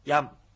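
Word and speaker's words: ヤㇺ: ヤム。 – ヤム。 – ヤム。 – はい。カタカナでヤムとなってますが、ムというのはちっちゃい、ムですので、口を閉じるだけの発音なので、ヤムとこうはっきり言うんでなく、ヤムという発音になります。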